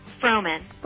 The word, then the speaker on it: Frohman: Frohman. – Frohman. Just like it's spelled.